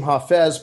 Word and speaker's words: Hafiz: They pronounce him Hafez, but it's spelled like Hafiz.